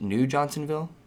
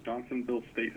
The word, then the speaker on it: Johnsonville: Yeah, and did I say that wrong? Is this your state park actually called New Johnsonville? – No, it's actually, you had it right the first time. It's Johnsonville State Historic Park.